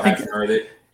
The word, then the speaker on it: Hachimura: Oh, Hachimura.